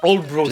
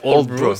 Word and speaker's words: Aldeburgh: S'appelle Oldbrough, ça s'écrit Aldeburgh, mais ça se prononce Oldbrough,